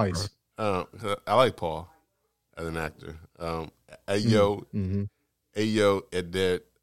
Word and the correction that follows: Edebiri: I don't know how she's pronounced her name. Ayo Edebiri, she won for The Bear, an excellent show.